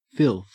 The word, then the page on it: filth: Australian English